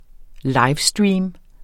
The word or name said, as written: livestream